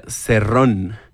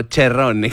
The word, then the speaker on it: Cerrone: No es por ser mamador, pero cerrone o cherrone, o como yo le dije cherrone, al ser francés se pronunciaría cerrón, no cherrone, como si fueras italiano.